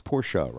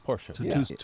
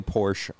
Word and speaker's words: Porsche: So a lot of people, who especially Porsche lovers, it's Porsche, right? – Yes. – Porsche. – Two-syllable word. – If I say Porsche, I'm clearly, no idea what I'm talking about.